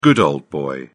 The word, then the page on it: good old boy: British English